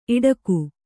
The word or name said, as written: ಈಡಿಗ